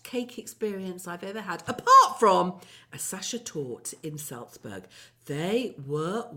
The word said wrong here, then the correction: Sacher: I'm just going to correct you on your pronunciation of Sacher Torte. It's Sacher.